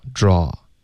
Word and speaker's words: draw: 这个字 draw a，这个 a 呢就有一点夸张了，然后我觉得大部分美国人就不会把这一个字发为 a，而会发 o draw draw，怎么样还是用一个倒 c 的发音，别的字上呢就不见得，可能就已经变成 a 了。